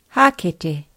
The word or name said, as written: hakete